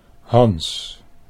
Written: Hans

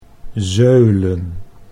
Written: zeulen

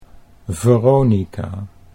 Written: Veronica

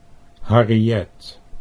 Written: Harriet

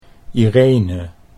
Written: Irene

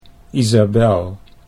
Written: Isabelle

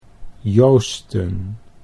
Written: Joosten

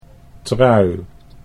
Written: Trui

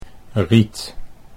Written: Riet

